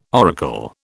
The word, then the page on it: oracle: British English